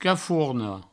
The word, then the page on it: caforna: cafourno